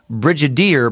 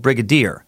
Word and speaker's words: brigadier: You pronounce it with a hard G, as as you're saying brigadier, but it's with a soft G, like brigadier, brigadier general.